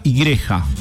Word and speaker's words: Igreja: Bueno, también nuestro canal oficial también de la JTA en portugués, que es JTA Igreja.